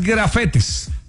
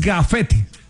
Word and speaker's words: gafete: porque no es grafetes. – Ah, ok. – Es gafete.